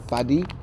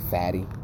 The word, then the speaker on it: Fady: Nigga, every other show after that, my next show was at Pinky Space with this dude named Fadi. Okay. I say it like that because it seems like you gotta say it like that. Mm-hmm. Because if not, it looks like Fatty. – Okay. – Yeah. – I imagine that. – F-A-D-Y.